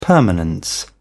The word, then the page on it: permanence: British English